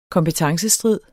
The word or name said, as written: kompetencestrid